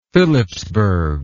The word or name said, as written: Phillipsburg